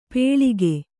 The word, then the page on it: ಪೇಳಿಕೆ: Kannada